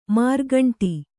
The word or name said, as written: ಮಾರ್ಗಂಟಿ